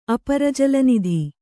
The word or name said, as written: ಅಪರಜಲನಿಧಿ